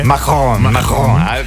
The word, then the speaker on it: Macron: È talmente diffusa questa abitudine che Emmanuel Macron, l'ho detto bene, Macron. Devi accentuare Macron.